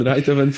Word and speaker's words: Rijthoven: we were mispronouncing Tim van Rijthoven. We were saying no, let, let you know. Let's not say how we were pronouncing it before, but it's gonna be Tim, Tim van Rijthoven from now on.